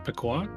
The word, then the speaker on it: Peychaud's: Beyond that, kept it pretty traditional right down to the Sazerac whiskey and the, I'm going to butcher this name, Peychaud's bitters. – Is it Pequot? – Oh, that's so much better.